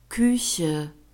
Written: kueche

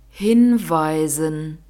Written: hinweisen

